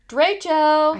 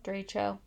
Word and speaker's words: Derecho: Derecho. – I can't say that right. My it doesn't form right. Derecho? Yeah. – Derecho.